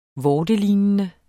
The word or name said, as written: vortelignende